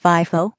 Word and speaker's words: FIFO: FIFO, First In, First Out, pronounced FIFO.